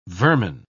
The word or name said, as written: vermin